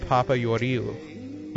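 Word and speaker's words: Papagiorgiou: Yeah, and then the last name is pronounced Papa Yoriu. Like, all the G's are soft, I think.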